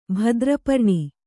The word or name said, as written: ಭದ್ರಪರ್ಣಿ